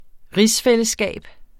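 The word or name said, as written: rigsfællesskab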